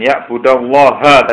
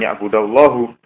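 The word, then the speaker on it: ya'budallaha (يعبد الله): An ya'budallaha tadi bacanya bukan ya'budallahu. Kalau dibaca hu berarti fa'ilnya lafzullah Allah, tapi yang baca ya'budallaha.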